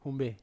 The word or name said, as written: humbe